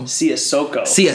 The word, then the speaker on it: Siasoko: Is it BJ Siasako or Siasako? – Siasoko. What kind of a friend? – I'm Siasoko. I have said your last name wrong for so long.